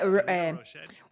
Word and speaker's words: Rochetti: Rochetti? Well, yeah, it's a Italian last name, so it doesn't matter. Ideally would be Rochetti, but I don't even pronounce it that way because I'm from Brazil. In Brazil, we say Rochetti, or in English, people say Rochetti, or Rochetti. So whatever feels good for you, feel free.